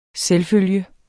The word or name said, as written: selvfølge